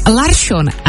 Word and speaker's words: Larsson: Z Larsona je najednou Larsson a z Persona Persson.